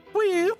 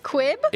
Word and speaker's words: Hwiip: Queep. – Quib? – H-W-I-I-P. – Queep. – Quip. – That's how it's pronounced. I don't know what to tell you. – Queep. – It's Elvish for you.